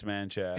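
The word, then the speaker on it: Manchaca: Man. How do you say it? – Manchac, it's Manchac. Apparently. – It's Menchaca. – That's what I was saying to somebody in my car the other day, and we busted out the Googler. Turns out it's Manchac.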